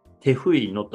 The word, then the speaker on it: Tejuino: で、そのよく飲む好きな飲み物っていうのはですね、テフイノと言います。えー、 t-e-j-u-i-n-o で、テフイノ。えー、スペイン語の発音だとテフイノになるんですけど、えー、僕、まあこれがむちゃくちゃ好きなんですよ。